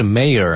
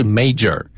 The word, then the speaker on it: mayor: 整个的节日将由市长宣布正式启动，正式开始。这个 mayor, M-A-Y-O-R, 就是市长的意思。 – 嗯，读音是 mayor, 啊，不是 major。